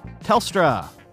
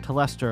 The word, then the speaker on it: Telstra: So thanks to all of you, especially you Australian listeners who got in touch over the last 24 hours and let me know it's pronounced Telstra. The Australian telecom company I tried to reference yesterday is called Telstra, not Telestra or whatever it was I said.